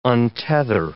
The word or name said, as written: untether